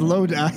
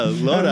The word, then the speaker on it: Lodi: I think it's pronounced Lodi. It just made me think of it. – Yeah, Lodi.